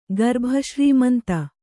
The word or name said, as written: ಗರ್ಭಶ್ರೀಮಂತ